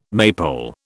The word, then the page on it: maypole: British English